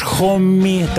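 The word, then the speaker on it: Homme: Великолепная группа Джош Хомми, так правильно произносится его имя.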